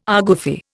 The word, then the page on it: argufy: Indian